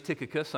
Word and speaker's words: Tychicus: I mean, if you do a very literal wooden transliteration from the Greek to English, then you could pronounce his name Tychicus. You know, like I took a kiss from Alicia, you know, yesterday, you know, Tychicus.